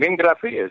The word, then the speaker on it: Ingraffia: All my life I've had to like kind of pronounce it for people because it has a lot of vowels in it. And then I was in Italy and I was working there and I started to spell it and pronounce it to everybody, and they're like, no, it's an easy name. Ingraffia is not difficult.